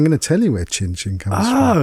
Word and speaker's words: Cin Cyn: Cin Cin. – So it's spelled C-I-N-C-Y-N. So it's a play on the Italian cheers, Cin Cin.